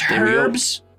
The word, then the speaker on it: herbs: It's pronounced herbs with a hard H.